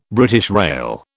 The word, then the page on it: BR: British English